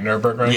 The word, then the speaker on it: Nürburgring: And the Nürburgring record, I said that right, Nürburgring? – Yeah, okay, Nürburgring.